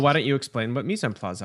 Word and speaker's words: mise en place: It's a three-word French term that rhymes with demi-glace. – Okay. – Well, the last part rhymes with demi-glace. – Mise en place.